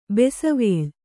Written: ಬಸವಳಿ

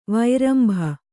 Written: ವೈರಂಭ

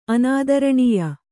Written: ಅನಾದರಣೀಯ

